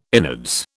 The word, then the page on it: innards: British English